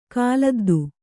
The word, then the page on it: ಕಾಲದ್ದು: Kannada